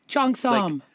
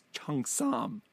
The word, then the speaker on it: cheongsam: But there's no real, there's no real hard G sound in it. It's Chung Sam.